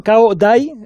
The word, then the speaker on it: Caodai: el caudismo, ¿no? – Caudaísmo. Es Caodai, es el nombre, y luego son caodaístas, nos cuesta un poco a nosotros pronunciarlo.